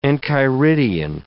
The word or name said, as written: enchiridion